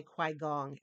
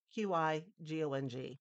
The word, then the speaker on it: Qigong: I want to say Kwai Gong, and that is absolutely wrong. That's how my brain processes it. But you know what I'm talking about. Qigong.